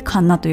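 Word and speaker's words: 神流: 神様の神に流れると書いてカンナというふうに読むそうです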